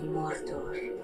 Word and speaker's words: Mordor: On his dark throne, in the land of Mordor.